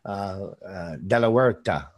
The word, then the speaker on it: De La Huerta: De La Huerta. You got pretty close. H is silent in Spanish. – Okay. I struggle with English, to be honest with you, sometimes. So De La Huerta.